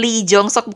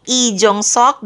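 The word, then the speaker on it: I Jong Sok (이종석): Congrats buat Lily Couple yang sebenarnya bacanya bukan Lee Jong Sok, bukan Lee Jian tapi I Jong Sok dan I Jian ya.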